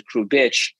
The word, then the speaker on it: Kubrick: And so, you know, me and my brother grew up with the last name and we would pronounce it Kubrick. But it's funny that you said that because just a week ago, he called me and said, look, someone who did the paperwork, our parents didn't speak English, the pronunciation of our last name to be Kubrick is subjective, right?